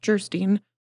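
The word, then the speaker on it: Geierstein: Anne of Gerstein, pronounced Gerstein, or The Maiden of the Mist, by the author of Waverley.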